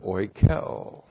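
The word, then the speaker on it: oikeo (οἰκέω): And then the verb oikeo. O-I-K-E long O. Oikeo.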